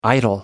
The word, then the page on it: idle: American English